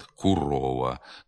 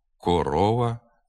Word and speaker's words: корова: Наприклад, «курова», «куза», «молодий», «дорога». Таке сильне укання невластиве для літературної мови. Голосний «о» в цих словах треба вимовляти без ніякого наближення до «у». «Корова», «коза», «молодий», «дорога».